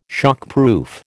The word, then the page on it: shockproof: American English